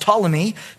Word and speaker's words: Ptolemy: And Ptolemy, spelled with a P there, pronounced Ptolemy, took over Egypt, Palestine, Petra, and Cyprus.